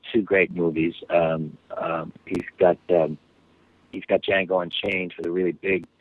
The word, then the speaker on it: Django: Django Unchained, and that D is silent.